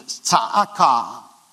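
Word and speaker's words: tsa'aka (צעקה): It's kind of hard to pronounce, but it's tsa'aka. It sounds like almost like a bird call.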